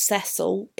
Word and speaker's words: Cecil: I do know that it's pronounced Cecil in English, in British English,